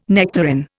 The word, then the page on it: nectarine: Indian